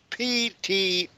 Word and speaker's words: Ptolemy: This is, verse 38 of chapter 3, Lysias chose Ptolemy, that's Ptolemy, the P is silent, son of Dorymenes, and Nicanor and Gorgias, G-O-R-G-I-A-S, or Gorgias, able men among the friends of the king.